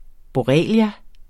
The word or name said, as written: borrelia